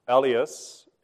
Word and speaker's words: Elias: Elias is the oldest. So it's like, think of pronunciation, think of Elliot with an S.